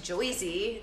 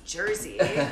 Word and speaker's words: Jersey: I know it's definitely not pronounced Joyzy, it's Jersey.